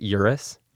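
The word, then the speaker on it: Uris: This is how you say it, Urus? – Yes.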